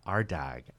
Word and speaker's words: Ardagh: Just the, the, the, second name, it's Arda. It's like Harda without the H.